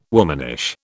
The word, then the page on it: womanish: British English